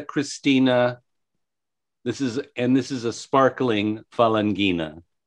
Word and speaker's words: Falanghina: So the Falanghia, and I'm probably mispronouncing that grape. I apologize to everyone who's listening. – That's Falanghina is the name.